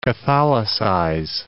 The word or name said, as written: catholicize